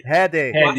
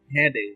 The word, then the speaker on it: Headey: Inclusive, eu acho que a Lena Headey, né? Se pronuncia. – Headey. – Headey, obrigado.